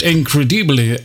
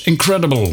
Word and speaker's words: incredible: Je hebt incredible uitgesproken op zijn Frans. Zal ik het dan maar in het Engels zeggen? Het was incredible.